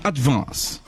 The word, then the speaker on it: advance: É um A puxando pro E, ó. Que vê a diferença, tá. Ó. Advance. Aí você falou advance, mas ó, é um E puxando, ó. É advance. – Advance. – Coloca advance. A mão assim que ajuda. É advance.